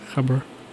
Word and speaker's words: Gabber: That's how they pronounce it in like Dutch, Gabber.